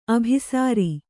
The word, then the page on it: ಅಭಿಸಾರ: abhisāra